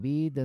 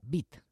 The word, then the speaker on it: Witt: Se escribe W-I-T-T. y se pronuncia bit, así, no wit. Eso sería inglés.